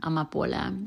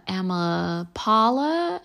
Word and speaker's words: Amapola: I didn't like my name, Amapola, because many people didn't know how to pronounce it. I'm a I'm a Paula, I'm a Paula, I'm a, uh Paola.